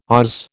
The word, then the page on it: Oz: American English